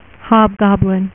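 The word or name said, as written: Hobgoblin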